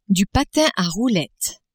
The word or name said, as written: patin a roulettes